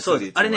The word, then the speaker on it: Thule: あれのマークでね、みんなしてありますよね、スーリー。あれね、読み方ね、スーリーって読むんですよ。ちょっと読めないんですよ、これ。 THULE で、なんて読むんかなと思って、あれスーリーと、まあ日本語では読むんですけど。